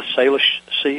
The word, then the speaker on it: Salish: And maybe, Denny, when we come back, you mentioned, am I pronouncing the Salish Sea, is that correct? – Yes.